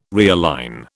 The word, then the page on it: realign: British English